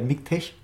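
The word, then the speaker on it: MiKTeX: Unter äh, Windows gibt es vor allem noch MIGTEX. Äh, MIGTech, das habe ich auch falsch gesagt.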